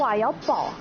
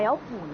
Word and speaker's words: 瓦窑堡: 为什么不读瓦窑堡而读瓦窑堡呢？ – 堡呢，就这个字儿啊，它有三个读音啊。第一个呢，就是我们非常熟知的堡，它指的堡垒、堡寨的意思。另外一个呢，读铺。